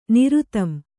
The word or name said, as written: ನಿರುತಂ